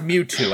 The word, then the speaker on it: Mewtwo: Is she worthy to carry Mew Mew? – Oh, definitely. – Mew Mew. – It's pronounced Mewtwo, Al.